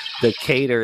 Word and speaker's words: Decatur: Decatur is how you say it, not you dumb New Yorker, Decatur.